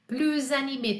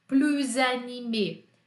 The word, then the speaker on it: plus animé: Plus animé. Tem meio que um som de Z, não tem? Plus animé plus animé.